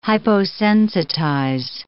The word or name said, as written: hyposensitize